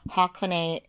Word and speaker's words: Hakone: So H-I is Hikone, Hakone is H-A, and Heikone is H-E.